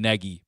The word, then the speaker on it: Nagy: Texter, you're wrong because Matt Nagy himself has pronounced his name both Nagy and Nagy before. So Strictly speaking, his last name is pronounced Nagy. He accepts both.